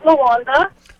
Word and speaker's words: Lawanda: This is Lawanda. – Lawanda? – Yes. – Spell that just for fun. – L-A-W-A-N-D-A.